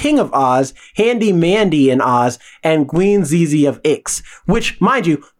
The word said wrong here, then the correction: Zixi: Queen Zixi of Ix. I said it... Pronounced it wrong the first time.